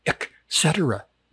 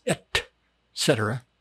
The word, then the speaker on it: et cetera: See, it's like people go around, they say, ek cetera. No, it's not ek cetera. It's et cetera.